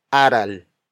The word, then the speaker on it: aral: For instance, the root word aral. Aral. Aral. Aral is spelled as A-R-A-L.